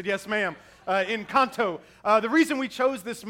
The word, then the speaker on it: Encanto: So it's pronounced Encanto, in case you don't know.